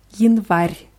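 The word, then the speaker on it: январь: Und das Wort fängt mit dem Buchstaben Ja an, wird aber eher wie J ausgesprochen. Also Janwar.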